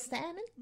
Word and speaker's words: salmon: Some grilled salmon? Salmon? – Salad? – So anyway, guys, I now know how to pronounce the word is salmon. – Salmon. – Salmon. Without the L is silent.